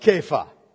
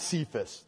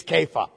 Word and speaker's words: Kepha: You shall be called Kepha." Not Cephas. That's the southern pronunciation. It's Kepha, okay? You'll be Kepha. Like Bo Cephas? Cephas, right? Yeah. Kepha, okay? That's what this is. It's Aramaic. It's Aramaic for rock.